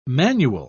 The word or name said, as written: manual